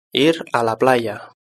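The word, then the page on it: ir a la playa: Spain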